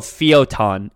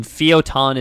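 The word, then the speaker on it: Fio'taun: So it's the city is called Feotan, and Feotan is spelled Fio apostrophe T-A-U-N. So F-I-O apostrophe T-A-U-N.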